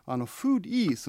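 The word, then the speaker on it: foodie: で、フードは食べ物なんですけれども、フーディー、その最後の IE、 E と発音するんですけれども、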